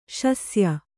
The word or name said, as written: ಶಸ್ಯ